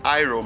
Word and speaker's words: Iroh: Ah, eu estou escutando ainda, mas a pronúncia de Hiro em inglês é Iro mesmo.